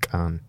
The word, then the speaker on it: qeej: Spelt Q-E-E-J, Gij, but I think you say it, Khan.